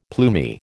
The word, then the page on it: plumy: American English